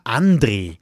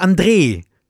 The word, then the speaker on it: André: Ganz viele Menschen sagen immer André, aber ich heiße André, also mit langen E, weil es ja irgendwie wie Andreas, da sagt man ja auch nicht Andreas.